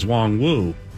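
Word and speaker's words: Nwangwu: And then all of a sudden, media relations got the hands in there and said, actually, it's Wong Wu.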